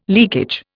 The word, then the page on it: leakage: Indian